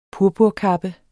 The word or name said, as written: purpurkappe